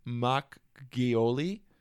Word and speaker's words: Makgeolli: You want to throw out a shout out to a Korean rice liquor, Makgeolli.